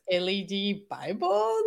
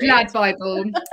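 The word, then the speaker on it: LADbible: I was like, is it LED Bible or is it Lad Bible? – Yeah, no, it's Lad Bible.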